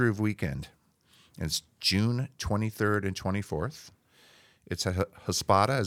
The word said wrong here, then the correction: Hospoda: is how you say it? – Hospoda? – I said it completely wrong. It's the opposite. It's Hospoda. Hospoda.